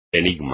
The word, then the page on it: enigma: espagnol